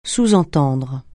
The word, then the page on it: sous-entendre: francais